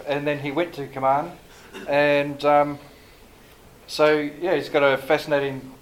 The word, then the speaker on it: Kaman: Is it Kaman or Kaman? – Kaman. – Okay. I always hear two different versions.